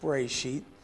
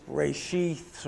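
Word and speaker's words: Bereshit (בראשית): Thus, in the beginning, Hebrew, and you can see the Hebrew there, that's Hebrew that's pronounced Bereshit. English pronunciation is Bereshith or Bereshit. Some people pronounce it Bereshith. It depends on where in the world you come from as a Jewish person, which dialect you speak, is the first word of the book of Genesis, and according to Jewish custom, the name of the book.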